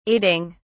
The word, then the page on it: aiding: British English